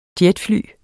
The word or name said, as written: jetfly